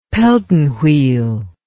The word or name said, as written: Pelton wheel